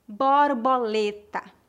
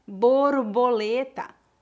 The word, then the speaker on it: borboleta: Eu lembro que um dia no colégio eu falei a palavra borboleta, com O aberto. E os meus coleguinhas acharam muito engraçado porque a pronúncia normal para eles é borboleta, com O fechado. As duas formas estão certas. São apenas variações de sotaque.